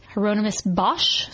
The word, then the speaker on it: Hieronymus Bosch: So, yeah, they have their roots in a famous Dutch painter named Hieronymus Bosch.